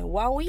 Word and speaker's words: Wawwe: Eu estou falando certo? É Huawei? Tá sim. – Tá ok, tá, sim. até então W-A-W-W-E.